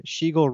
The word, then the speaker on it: Shegel: It's a Shegel rhymes with eagle, beagle, legal, or regal.